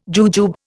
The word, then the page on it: jujube: Indian